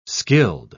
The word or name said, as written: skilled